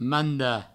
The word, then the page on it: mandar: manda